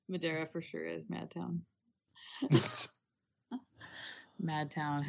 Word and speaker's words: Madeira: I'll be in Madeira. It's pronounced Madara.